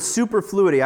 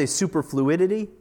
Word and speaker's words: superfluity: And It speaks of, this is a new word, superfluity. I would say superfluidity, but that's not how it's spelled.